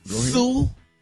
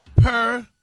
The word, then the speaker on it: superfluous: Sue. S-O-O. – Uh-huh. – Per. – Come on. – P-E-R. Blue. F-L-O-O-O. F-L-O-O. Us.